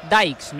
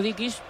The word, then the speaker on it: Dykes: Hemos quedado, Miguel, Alex, que el 9 es Dykes, ¿no? – Sí. – Vale, yo lo había dicho Dickies pensando que era pronunciación escocesa, pero no.